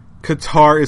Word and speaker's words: Qatar: It's Qatar. Qatar is how you say the name.